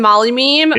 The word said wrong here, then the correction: Molyneux: It's pronounced Stefan. Oh, Molyneux.